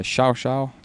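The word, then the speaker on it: Llao Llao: So one of the treks I did was the Xiao Xiao trek, which is spelled LLAO, Laolao, it would be in English, but the Argentinians pronounce it Xiaoxiao,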